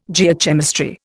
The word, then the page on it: Geochemistry: Indian